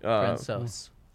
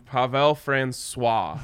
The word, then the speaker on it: Francouz: On ESPN last night, they were calling him Pavel Francois, as if he was like French, and it was spelled C-O-I-S, but it's spelled C-O-U-Z. It's not even close to Francois.